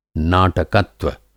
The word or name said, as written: नाटकत्व